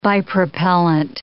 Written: bipropellant

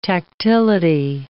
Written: tactility

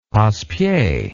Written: passepied

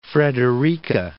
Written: Fort Frederica National Monument

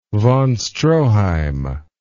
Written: von Stroheim

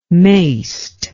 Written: maist

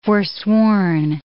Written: forsworn

